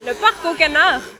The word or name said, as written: le parc aux canards